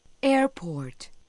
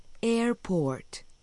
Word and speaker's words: airport: La O de port se alarga. Recuerde que debe pronunciar la T final. Repita. Airport. – Una vez más, la R generalmente no se pronuncia en inglés británico, pero sí se escucha en el inglés estadounidense.